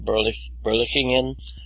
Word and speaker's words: Berlichingen: Anim, your pronunciation of Goetz von Berlichingen and Goethe is pretty spot on, although the CH is a palatal fricative.